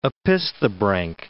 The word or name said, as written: opisthobranch